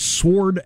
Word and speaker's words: sword: They think they found the oldest sword ever. – Wow. – Did you pronounce, I know you're anti-silent letter. – Yeah, I am. – How do you pronounce S-W-O-R-D? – Sword.